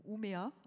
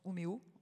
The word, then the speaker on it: Umeå: Vienne a anticipé depuis 30 ans, ou Umea, ça se prononce Umeå en Suède, qui d'ailleurs aujourd'hui a eu les honneurs de Marie-Claire.